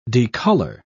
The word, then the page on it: decolor: British English